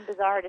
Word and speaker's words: Buzard: And my real last name, Buzard, is so difficult to pronounce and tricky for people that Laura B.